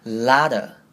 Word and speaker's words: a lot of: 是连音成 gotta, 那这边 lot of 我们要连成 ladder, 那有点像是把 t 变成的声音，然后把 of 变成只有 a，所以念成 ladder, a lot of times.